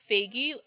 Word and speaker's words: Faigy: It's Faigy, F like Frank, A-I-G-Y.